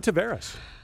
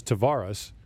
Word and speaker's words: Tavares: And John Tavares. – What Morris. Is it Lindros? I think it's Lindros. Okay. And John Tavares' mom says Tavares, and his dad says Tavares. Craziness.